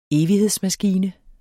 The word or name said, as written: EVIGHEDSMASKINE